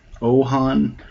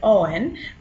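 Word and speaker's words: OAHN: So we're working on this program called the Ontario Animal Health Network, and I pronounce it OWAN, but I guess you could pronounce it just about however you want. I guess I feel like OHAN is a bit Lord of the Rings for my stuff, but OWAN is how I say it.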